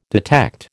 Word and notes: detect: American English

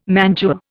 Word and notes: mantua: Indian

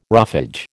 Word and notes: roughage: American English

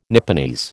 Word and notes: Nipponese: American English

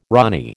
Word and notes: rani: American English

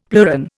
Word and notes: pleuron: Indian

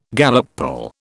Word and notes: Gallup poll: British English

